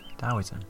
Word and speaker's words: Taoism: So, Taoism, spelt with a T, not a D. That sounded very teacher-like, didn't it? Fucking hell. It's spelled with a T, guys. Oh, I did it again. I said guys again. Oof. It's spelled with a T, friends. But it's pronounced Taoism.